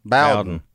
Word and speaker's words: Bowden: When Bobby Bodden. – Bowden. – Well, I can't say it right. – B-O-W-D-E-N.